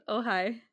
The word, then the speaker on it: Ojai: Originates in Ojai, California. I think that's how you say it. Or, or Ojai? Ojai. – Ojai.